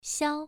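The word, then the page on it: xiao: tone 1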